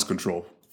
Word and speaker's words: CTRL: The branding is CTRL, but it's pronounced as Control.